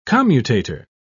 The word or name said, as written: commutator